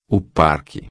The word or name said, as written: parque